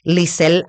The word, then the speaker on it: Lizelle: Instagram, Facebook, Lizel Arzuaga,